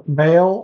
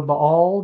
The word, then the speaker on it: Baal: He killed 450 prophets of Baal or Baal, depending on how you pronounce it.